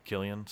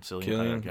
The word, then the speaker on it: Cillian: Is it Cillian Murphy? – Yeah, Killian. – Killian.